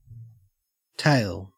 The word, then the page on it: tail: Australian English